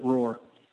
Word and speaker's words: R04R: R04R is how we spell it, but we pronounce it Roar.